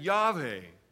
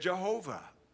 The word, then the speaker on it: Yahweh: That's as in Yahweh. Mispronounced today as Jehovah.